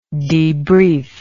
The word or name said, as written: debriefing